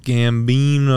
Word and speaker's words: Gambino: Childish Gambino. – No way is that how you really tried to pronounce his name. – No, it's Gambino.